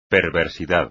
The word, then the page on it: perversidad: Spanish